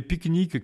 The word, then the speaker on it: pycnique: Alors pique-nique, ça s'écrit PYCNIQUE, pas comme le pique-nique qu'on va faire au parc, mais...